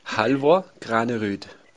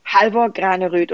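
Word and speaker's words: Halvor Granerud: vielleicht für manche in Deutschland, die noch nicht so ganz genau wissen, bitte sag's doch mal, wie spricht man den Namen korrekt aus? Hal Halvor Granerüd. – Halvor Graneröd.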